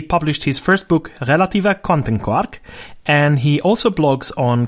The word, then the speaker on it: Quantenquark: Relativa Quantenquark.